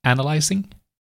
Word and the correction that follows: anti-aliasing: Yes, you did. Anti-aliasing.